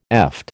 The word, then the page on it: eft: American English